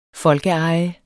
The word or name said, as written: folkeeje